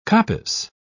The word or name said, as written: coppice